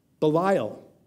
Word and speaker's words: Belial: And one thing you should know is that in Hebrew, the word for worthless is pronounced Belial.